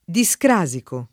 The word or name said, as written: discrasico